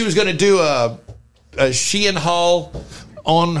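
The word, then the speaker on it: Shein: Full disclosure, I thought this business is pronounced... Sheen, but it's pronounced Sheehan.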